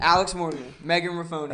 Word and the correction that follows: Rapinoe: What'd you just say? – I don't know how to say it. It's Megan. – I thought it was Rapinoe. – Rapinoe.